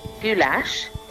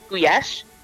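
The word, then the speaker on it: gulyás: The dish that we know is goulash in this country, which more properly apparently is pronounced guyesh, although I'm very prepared to be corrected on that one, is actually not the dish that you will find under that name in Hungary.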